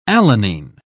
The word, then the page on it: alanine: British English